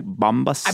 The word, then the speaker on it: Bombas: Oh, also, am I saying that right? Bombas? – I believe so.